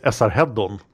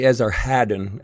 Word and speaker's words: Esarhaddon: But in Swedish, we say Esarhaddon. – I think you said it correctly. – I don't think so. – I will try to say Esarhaddon every time I say it because we're going to be saying it a lot.